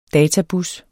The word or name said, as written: databus